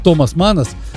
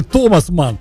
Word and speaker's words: Томас Манн: В Литве до сих пор проблема, как правильно говорить, Томас Манас. Когда говоришь, что он вообще не Манас, а он Манн в оригинале, они говорят, да, ну, значит, тогда Том Манн. Нет, все-таки Томас Манн.